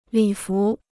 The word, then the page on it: 礼服: lǐ fú